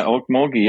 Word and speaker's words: Okmulgee: I was born in Okmulgee, Oklahoma. – See? – My dad. – Who the hell knows how to pronounce Okmulgee? – That's right. Yeah, Okmulgee.